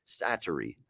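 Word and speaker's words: Säteri: But no, it's Sateri. That's how you say it.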